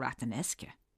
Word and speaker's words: Rathanesca: It's spelled as if it should be pronounced Rathanesca, but for whatever reason, we locals have always called it Rathanesca.